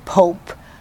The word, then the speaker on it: Pope: It could be, it would be without the E on the end, it would be pop. – Yeah. – But with the E on the end, it in English would be Pope. And they pronounced it in the Romanian language as Pope, but it was spelled P-O-P.